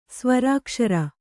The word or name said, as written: ಸ್ವರಾಕ್ಷರ